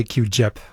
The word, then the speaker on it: QGIP: You've created what's called, are you calling it QGIP, G-I-P, right? – Yeah, to me, it sounded better to say QGIP with a soft G.